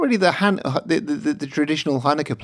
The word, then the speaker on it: Hanukkah: Yeah, and then let's also get the pronunciation right. It's Hanukkah. You got to get the Hanukkah.